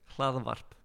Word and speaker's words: Hlaðavarp: How do you say the name of it? – Hlaðavarp. – Hmm? What's Cladavarth? Podcast. Is it? Mm-hmm. Say it again. Hlaðavarp. – Hlalavarth. – Exactly.